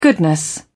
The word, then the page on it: goodness: British English